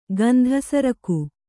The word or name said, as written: ಗಂಧಸರಕು